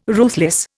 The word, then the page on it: ruthless: Indian